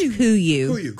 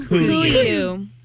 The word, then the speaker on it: Kuiu: K-U-I-U. – It's say they came up with the name as a hunting call. It's a hunting call for like a bird or something. – It's pronounced Kuyu. – Kuyu.